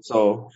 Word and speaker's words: Seoul: Do you know how to say it? – Seoul, just Seoul.